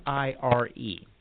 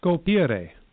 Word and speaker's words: scolpire: I'll spell it Scolpire. It's Italian, so follow Italian. – Scolpire, which means to carve or to sculpt. – Right, scolpire. That's how you would say it.